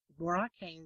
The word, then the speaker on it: Boracay: Boracay is an island in the Philippines.